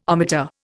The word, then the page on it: ohmmeter: Indian